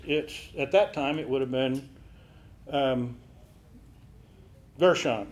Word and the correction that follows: Goshen: No. – Goshen. – I can't make that long O fit my word today. Goshen.